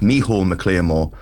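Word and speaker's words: Micheál Mac Liammóir: No, it was first done in 1960 in Dublin by the man who wrote it it's spelt Michael McLearmore, it's pronounced Micheál McLearmore,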